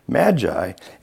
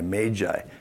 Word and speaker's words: magi: really should be pronounced M-A-Y-J-I, magi. Nobody does that, just magi. So we're going to stick with magi.